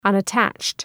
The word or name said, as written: unattached